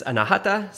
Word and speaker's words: Anahata: It's Anahata, so like the accent is on the the second a.